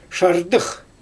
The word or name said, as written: ʃardɯx